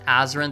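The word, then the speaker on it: Azren: That is spelled P-O-L-Y-G-L-O-T-A-Z or Z, depending on your country, R-E-N. You can also simply search Azrin the Language Nerd on whatever your favorite social network is.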